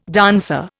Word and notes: dancer: Indian

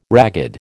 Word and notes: ragged: American English

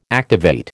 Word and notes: activate: American English

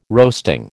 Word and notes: roasting: American English

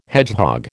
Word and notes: hedgehog: American English

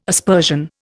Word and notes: aspersion: Indian

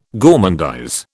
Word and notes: gormandize: British English

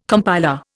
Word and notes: compiler: Indian

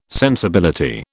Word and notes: sensibility: British English

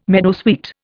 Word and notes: meadowsweet: Indian

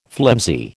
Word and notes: flimsy: American English

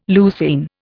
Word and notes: leucine: Indian

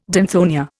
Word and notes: danthonia: Indian

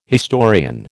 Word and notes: historian: American English

